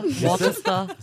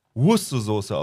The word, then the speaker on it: Worcestersoße: Worcester. – Siehst du, habt ihr alle falsch ausgesprochen. Es wird nämlich Worcestersoße ausgesprochen.